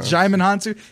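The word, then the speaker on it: Djimon Hounsou: I need to find the actor because Digimon Hansu, is that how you pronounce it? – Jaimon Hansu.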